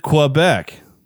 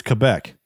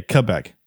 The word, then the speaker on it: Quebec: You pronounced it Quebec. You pronounced it like Americans do. I've always been told it's Quebec, like with a K, Quebec.